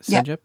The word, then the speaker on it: Cégep: So then um, you finished with is you called it Cégep? Is that how you say it? – Cégep? Yeah, yeah, that's it.